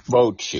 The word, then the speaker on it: bocce: Was it pronounced Bochy or Bocce? – Bochy.